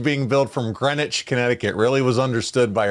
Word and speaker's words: Greenwich: Greenwich. – He put Greenwich, but yeah, Greenwich. There you go. – Yeah, well, it's spelled Greenwich. It is. It's Greenwich.